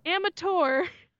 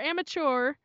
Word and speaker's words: amateur: He did not pronounce amateur to rhyme with blur. He said amateur or amateur, which is a totally valid way to say amateur. Amateur, amateur. But you're rhyming with blur. Say it to rhyme with blur, please.